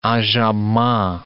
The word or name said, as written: enjambement